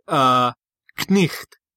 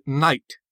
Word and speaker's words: knight: We no longer pronounce the word, Knicht. We pronounce it knight. – Mm-hmm. – But we still keep the K and the GH in there.